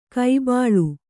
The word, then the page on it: ಕೈಬಾಳು: kai bāḷu